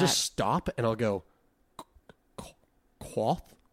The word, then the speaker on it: Kvothe: I've settled on Quoth. – Quoth. – Quoth. It's spelled K V O T H E.